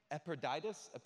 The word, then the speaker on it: Epaphroditus: Epaphroditus, that's his name.